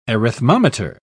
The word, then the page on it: arithmometer: British English